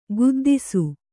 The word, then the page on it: ಖಿದ್ದಿಸೆ: khiddise